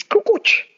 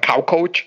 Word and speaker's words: Kukoc: It's Kukoc. Kukoc is really easy, but like Kaukoc, Kukoc, is like they were butchering it so hard, but it was the funniest thing.